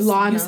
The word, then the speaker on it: Lana: And I don't know, okay, first of all, I don't know if you pronounce it Lana or Lena. How do you Lana. – It's Lana Del Rey.